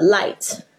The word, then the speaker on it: light: 有时候很多 g h 是不发音，比如说像呃 light。或者是 fight，呃，night，然后你就发现这 i g h t 呢，i g h 那个 g h 里面都没有发音，所以你就可以这样子去记忆这单词，就会发现，哎，好多字其实都已经背过它的一部分的东西了。